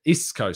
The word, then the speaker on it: Isco: Isco, sorry. Someone corrected me in the comment sections of a previous video. I've been pronouncing it in a Portuguese way.